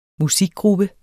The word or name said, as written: musikgruppe